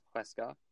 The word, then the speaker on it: Huesca: Their first game was against Huesca. That is how it's pronounced.